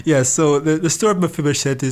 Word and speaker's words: Mephibosheth: And the story you've brought forward today is, I'm going to try to sound this out, is the story of Mephibosheth. – Yes. – Found in the Old Testament. – Okay, yes, and I think you have said it correctly.